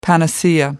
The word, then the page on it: panacea: American English